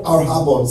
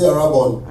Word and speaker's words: arrhabon: The Greek word for earnest there is the word arhabon. Say arhabon. I'll spell it for you. A-R-R-H-A-B-O-N.